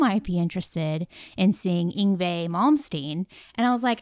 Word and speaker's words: Yngwie Malmsteen: Dad would be happy to know that I have finally listened to, I'm going to butcher this name, Yngwie Malmsteen.